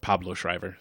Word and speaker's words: Pablo Schreiber: I again watched it this time looking at Merriman again, Paulo Schreiber. Mm-hmm. Pablo Schreiber. – I was like, is he pronounced it that way? Sorry, it's Pablo.